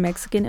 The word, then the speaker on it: Mexica: Mexican American Studies courses. – Just embracing that the word Mexico is, you know, derives from Mexica, which is our native indigenous history. The X is pronounced CH, and then that's where the word Chicano derived from.